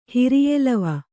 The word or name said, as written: ಹಿರಿಯೆಲವ